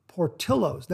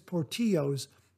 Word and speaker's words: Portillo's: The attached picture is of a burger we get from Portillo's. Now, that's spelled P-O-R-T-I-L-L-O apostrophe S, and I believe that's how it's pronounced. I don't believe it's Portillo's, the Spanish pronunciation.